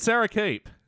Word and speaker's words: Sara Keep: And Sarah Keep.